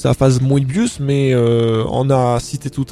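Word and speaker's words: Möbius: ben ça s'écrit M-O-Tréma, en allemand, hein, O-Tréma, ça se prononce E, Moebius.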